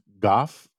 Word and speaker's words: golf: I do have to ask you, I believe there are people who argue that the proper pronunciation of golf is goff. And are you doing that because you have a Michigander accent, or are you doing that because you are tipping your cap to the Scots? What, because it sounds like you're not pronouncing the L very strongly or at all when you say golf.